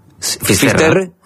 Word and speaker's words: Finisterre: Entonces, llegó a Finisterre. ¿Se dice así? – Finisterre.